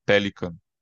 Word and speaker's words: Pelikan: e tem também o Yaroslav Pelikan, que foi traduzido no Brasil, se escreve Jaroslav Pelican.